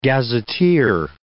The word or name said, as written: gazetteer